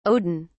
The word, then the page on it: odin: British English